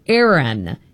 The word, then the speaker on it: Ayran: It's called, I'm not sure if I'm pronouncing it correctly, Ayran, A-Y-R-A-N, Turkey's beloved yogurt drink.